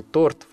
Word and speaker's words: торт: Ну а потом Алина просто идет в какую-нибудь проверенную кофейню с грамотными бариста, где совесть ей позволит съесть этот замечательный торт, в котором ударение всегда на первом слоге.